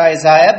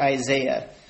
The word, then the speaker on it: Isaiah: We're not going to go to Isaiah, but turn to Matthew 11. I guess I should say Isaiah, but in South Africa, everybody pronounces it Isaiah, so that's how I've come to pronounce it now.